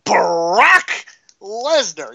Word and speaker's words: Brock Lesnar: Brock Lesnar.